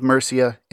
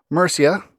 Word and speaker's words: Mercia: But it is an opportunity to briefly touch on its setting, the Kingdom of Mercia in the 9th century. Mercia, or Mercia, you'll find credible people using both pronunciations.